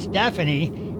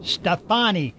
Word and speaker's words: Stefani: it's pronounced Stefani. How many times do you think she and her brother have had to say, it's not Stephanie, it's pronounced Stefani.